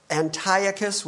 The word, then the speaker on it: Antiochus: And then in 167 BC, the Seleucid king Antiochus IV invaded Judea Antiochus the Fourth, you should know his name, or Antiochus, whichever way you want to pronounce it.